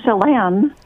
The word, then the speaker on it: Chelan: Chelan, Washington. Am I saying that right? Listening on KBCS? – Um, Chelan. – Chelan, okay.